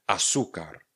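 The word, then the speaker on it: azúcar: Třeba slovo asukar. Píšeme azucar. Azucar píšeme, vyslovíme asukar. Tady máme to vyložení jako esko. Asukar. Na konci máme C a R. No a víme, že C a A se vysloví jako K, tedy K. Takže celé slovo je asukar.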